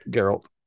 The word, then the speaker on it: Geralt: That's how you say it, yeah, right? – Geralt. – Yeah, he's going to play Geralt instead of Henry Cavill. – Or Gerald. – Geralt. – No, with a D, whatever.